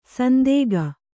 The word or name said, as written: ಸಂದೇಗ